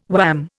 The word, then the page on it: wham: Indian